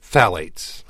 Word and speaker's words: phthalates: Phthalates.